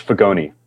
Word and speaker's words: Fagone: So it's Fagone. It's a long E.